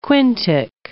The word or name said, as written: quintic